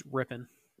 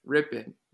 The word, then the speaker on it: Rypien: I hope I'm saying that correctly. It's Rypien. – Rypien. Brett Rypien Sorry, I'm I'm not familiar with irrelevant Boise State quarterbacks.